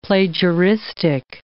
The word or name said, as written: plagiaristic